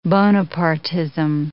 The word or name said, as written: Bonapartism